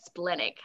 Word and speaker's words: splenic: We're again. Australians. We're splenic, honey. – Yes. – No splenic, splenic around here. Yeah. No splenic.